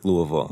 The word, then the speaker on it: Louisville: Yeah, Louisville.